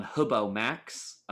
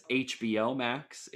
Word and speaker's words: HBO Max: So if you want to watch it, uh, it is available on Hubo Max. Uh, that is HBO Max. If you mm-hmm. pr- pronounce it by letters and not by word, um, and uh, just small content warning.